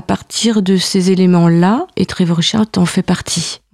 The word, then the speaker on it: Trevor Wishart: Est-ce que tu peux nous parler de Trevor Wishart, si je prononce bien? – Oui, tout à fait bien.